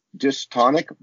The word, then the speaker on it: dystonic: What do psychologists mean when they talk about dystonic versus syntonic? – Beautiful.